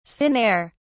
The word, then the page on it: thin air: British English